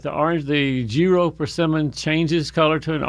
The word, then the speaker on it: Jiro: And there's one in particular, Vicki, called Giro, J-I-R-O, Giro.